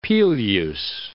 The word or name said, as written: Peleus